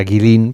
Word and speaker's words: Guilin: Bueno, esta ciudad de Guilin, por si alguien la busca en internet, se escribe G-U-I-L-I-N, como si fuera Guilin, pero se pronuncia Guilin.